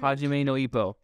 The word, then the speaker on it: Hajime no Ippo: such as Hippo Jimo no Ippo, the boxing anime. – Oh yeah, that one was huge. – Hajime no Ippo. – Yeah. – There we go. I butchered it the first time so hard.